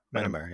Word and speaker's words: Myanmar: Myanmar, yeah. – So the Chinese embassy is also in Myanmar, and then you have the abattoir, which is in Myanmar.